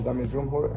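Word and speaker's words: Damir Džumhur: No, mira, te voy a decir cómo se dice exactamente Zumjur. – Venga. – Dale, Javi. – Hello everyone, my name is Damir Zumjur and I'm coming for Bosnia and Herzegovina.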